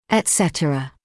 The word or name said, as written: etcetera